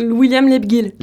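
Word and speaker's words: Lebghil: La vie de ma mère, un film drôle et émouvant signé Julien Carpentier, donc son premier film avec Agnès Jaoui, William Leguil, comment tu prononces ? – William Leguil. – Leguil.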